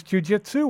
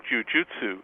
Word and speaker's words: jujutsu: Was it Japanese jujitsu or was it Brazilian? – Jujutsu, yeah, just traditional Japanese jujutsu. – Mm-hmm. – That you pronounce it jujutsu.